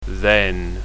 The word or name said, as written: then